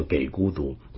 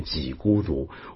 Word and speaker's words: 给孤独: 首先呢，我在上回星期三的番外里面啊，道歉提到这个我常念给孤独长者或者给孤独长老啊，那么我把它念成叫给孤独，但有朋友指正我应该念几孤独，我就向你各位求教啊，到底该怎么念才对。